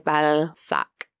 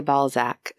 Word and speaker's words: Balzac: Vidocq was also close friend of renowned French author Honoré de Balzac. In America, we say Balzac, so I gotta get used to this pronunciation.